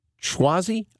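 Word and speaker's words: Suozzi: Schwazi, I think is how you say this guy's name. It's S-U-O-Z-Z-I.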